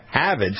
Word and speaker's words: Harvard: it's pronounced around here, Havid, so it's H-A-V-A-D.